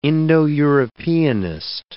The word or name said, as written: Indo-Europeanist